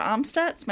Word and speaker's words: Amstutz: You can find all of my social media handles at Sandra Amstutz. My last name is spelled A-M-S-T-U-T-Z.